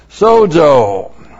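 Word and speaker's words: sozo (σῴζω): Sozo. Looks like that in the Greek, S long O, Z long O, sozo.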